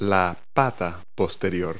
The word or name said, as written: hind leg